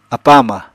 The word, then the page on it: apa-ama: Apatani